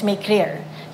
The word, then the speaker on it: Makerere: You spell it Makerere. spell it Makirere, but it's Makerere, University of Makerere.